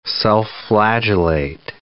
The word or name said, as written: self-flagellate